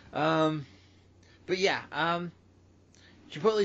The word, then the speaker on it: Chipotle: Chipotle is wrong. Chipotle is correct.